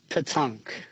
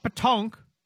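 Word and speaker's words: pétanque: It's pronounced Patunk. – Patunk? Oh, hit a K sound there. – Yes. – Okay. Patunk. Okay, yep. Not Patonk, Patunk.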